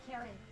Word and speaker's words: Kari: Carrie. – Spell it. – K-A-R-I. – K-A-R-I? – Uh-huh.